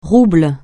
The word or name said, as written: rouble